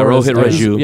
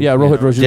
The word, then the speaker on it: Rohit Raju: The next match that moved on was Scarlett Bordeaux versus Rajit Raju, I think. – Yeah, I can't pronounce his name. – Raju. – We've seen him before. – Yeah, Rohit Raju. – Rohit Raju.